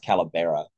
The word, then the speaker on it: Calavera: Calavera. I think it's pronounced Calabera, actually Spanish.